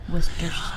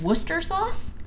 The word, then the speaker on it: Worcestershire: Actually, could you explain to me what Worcestershire? – Worcestershire But sauce, to my understanding, Isn't it just Worcester sauce? – that's how sure. that's how you that's how it's pronounced Worcestershire.